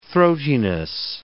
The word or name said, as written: throatiness